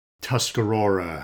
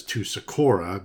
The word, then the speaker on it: Tuscarora: And another fan came up with this correction, and I want to apologize to the students, staff, alumni of Tuscarora High School. Apparently, I pronounced it as Tusacora because I misread it and repeated that mispronunciation, you know, when talking about Daniel Chica's history.